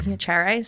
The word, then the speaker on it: Piacere: Piacere. So it's Italian. – Piacere. – You are actually one of the few people who got the pronunciation closest. – Piacere. – Yeah. You threw a little Spanish flair in there, but you got it pretty close.